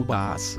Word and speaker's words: Boaz: A palavra Boaz é pronunciada em hebraico como Baas.